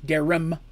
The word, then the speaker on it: Darum: She pr- she tells them that they need to seek out a man na- named Darren Smithson, Smith's son. Sorry, I said that wrong. Darren Darum with an M at the end, not an N as in Nancy, M as in Mary.